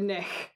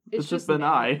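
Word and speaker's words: bean-nighe: So it's called the Benai. It is spelled B-E-A-N hyphen N-I-G-H-E. – Okay. – Because when I was, like, typing it out, I was like, oh, the Benich. And then... – The Benich. – And then I, like, looked at the pronunciation just to make sure, and they were like, it's just Benai.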